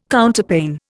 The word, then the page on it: Counterpane: Indian